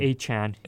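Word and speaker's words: Achane: How do you pronounce? Is it Devon Achan or Achan? – Achan. – Achan. – Achan. Okay.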